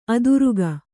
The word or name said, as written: ಅದುರುಗ